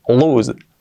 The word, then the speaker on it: lose: Escrito lo sé, la pronunciación es lose, lose, así, lose.